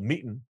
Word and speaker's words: Meetin: And now I'm back on the for-profit side, launching a Zoom competitor called Meetin, and that's spelled M-E-E-T, like meat, and then the letter N is Nancy.